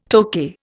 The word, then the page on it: Tokay: Indian